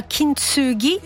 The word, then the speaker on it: Kintsugi: nuova canzone di Elisa si scrive Kintsugi con la K, si pronuncia Kintsugi.